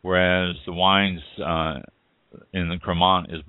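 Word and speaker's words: Crémant: then check out the ones that say Cremant, C-R-E with the little mark above the C-R-E-M-A-N-T, pronounced Cremant.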